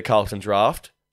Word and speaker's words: Carlton Draught: When I take a sip of the Carlton Draft, pronounced correctly, thank you very much,